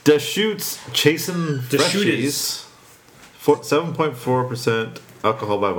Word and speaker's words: Deschutes: Deschutes. – Deschutes Rendition, which is featuring Centennial hops this year. I was quite optimistic for this one because Deschutes is a top-tier operation, and Centennial is the dominant hop in Bell's Two Hearted.